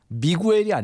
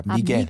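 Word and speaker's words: Miguel: 이게 가수가 미구엘이 아닙니다. 스페인어 발음으로는 미겔입니다. 미겔. – 미겔. – 네, 미겔이라고 하는 게 훨씬 더 정확한 발음입니다.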